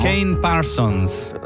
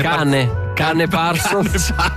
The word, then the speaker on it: Kane Parsons: Kane Parsons. Non ti piace? – Cane Parsons.